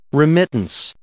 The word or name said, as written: remittance